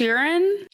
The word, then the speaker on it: Joran: It's spelled J-O-R-A-N, but it's pronounced Urine.